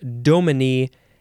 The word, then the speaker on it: dominie: So they just changed it in Scotland to dominie. And number two would be pronounced dominie.